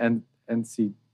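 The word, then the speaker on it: NCTzen: How do you pronounce it? – Oh, I know. I pronounce it NCitizen. – NCitizen. That makes sense to me. – Yeah. – As opposed to like a netizen.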